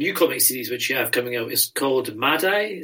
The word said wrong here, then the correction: Madi: Is that how you say it? Madi. Madi, Madi sorry.